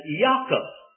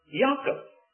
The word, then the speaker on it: Yaakov: Now, the Jewish people would pronounce the Jacob as Yaakov. That is, the J would be pronounced as a Y, Yaakov.